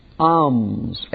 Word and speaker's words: alms: Alms, A-L-M-S. Alms. I think the L is silent properly. Alms. Like psalms. Alms.